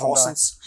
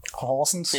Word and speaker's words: Horsens: Horsens. – Horsens, ja, genau.